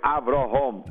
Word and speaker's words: Avraham: You had to make underline, because when God says to Avraham, Avraham, then we want to make sure that everyone pronounces the proper way, Avraham.